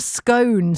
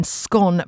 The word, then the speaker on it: scone: Lots of people use the gone as in scone pronunciation like I do, whilst areas in the East Midlands favour cone like scone and cone.